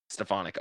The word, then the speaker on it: Stefanik: Stefan Stefanik, did I get his name right? Mike Stefanik. Yep. Stefanik. All right, yeah, and yeah, he was just he was just a legendary driver.